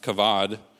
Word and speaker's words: kavod: And so the word in the Bible is pronounced kavod.